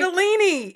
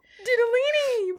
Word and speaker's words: ditalini: Didalini.